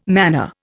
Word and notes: manna: Indian

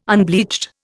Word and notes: unbleached: Indian

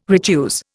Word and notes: retuse: Indian

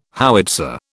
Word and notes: howitzer: British English

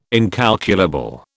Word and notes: incalculable: British English

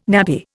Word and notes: nappy: Indian

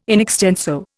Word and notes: in extenso: Indian

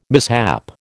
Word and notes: mishap: American English

